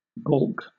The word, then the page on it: balk: British English